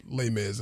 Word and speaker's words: Les Mis: I don't know why I try to pronounce it as it's okay. – as it's written, but Les Mis is Les Mis, how everybody knows the show.